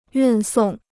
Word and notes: 运送: yùn sòng

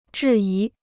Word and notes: 质疑: zhì yí

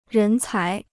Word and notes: 人才: rén cái

